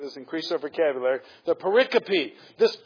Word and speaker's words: pericope: Is it bisyllabic or quadrisyllabic? It's quadrisyllabic. It's pronounced pericope.